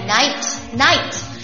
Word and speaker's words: knight: My knight K 没有发音啊，就是夜晚的那个单词加了一个 K，但是 K 在这里没有发音。